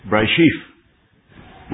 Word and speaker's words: Bereshith: Now the Hebrew title of Genesis is pronounced Bereshith, which means in the beginning, and that's why Genesis begins, in the beginning God created the heavens and the earth.